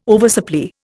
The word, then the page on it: oversupply: Indian